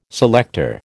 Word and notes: selector: American English

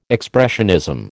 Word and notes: expressionism: American English

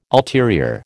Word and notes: ulterior: American English

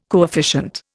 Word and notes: coefficient: Indian

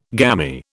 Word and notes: gamy: British English

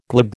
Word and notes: glib: American English

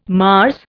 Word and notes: Mars: Indian

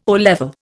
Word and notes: O level: Indian

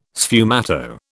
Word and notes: sfumato: British English

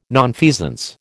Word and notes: nonfeasance: American English

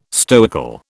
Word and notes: stoical: British English

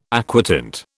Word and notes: aquatint: British English

